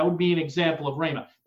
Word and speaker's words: rhema: The word here, again, is that Greek word rhema, transliterated R-H long E-M-A, rhema.